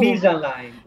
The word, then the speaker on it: Invisalign: Come si pronuncia? Dimmi tu. – Invisalign. – Invisalign, così? Ecco.